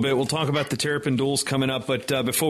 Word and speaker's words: Terrapin: Tell us a little bit about the fundraising there with the Terrapin pool. And I'm putting the emphasis on the pin there for a reason.